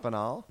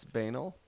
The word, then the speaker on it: banal: It's not banal. – Banal? – It's not. It's banal. – I've never heard this word before. – B-A-N-A-L.